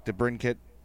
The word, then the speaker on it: DeBrincat: He says, which young Blackhawk, DeBrincat, Oh, I said it right. Hartman, Schmaltz. – Four... you said it right.